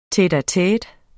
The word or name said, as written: tete-a-tete